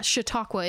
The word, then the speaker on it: Chautauqua: I said it right. Yeah, Chautauqua. It's Chautauqua. Chautauqua. Yes, yeah.